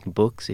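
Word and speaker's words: Boox: I go by the artist name of Books Kid, so it's B-O-O-X, but it's Books if you want to... – I love that. – ...say it properly.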